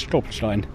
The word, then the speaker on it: Stolperstein: And when did you come across the stones, the Stolperstein, if I'm saying it right? – Yes, Stolperstein.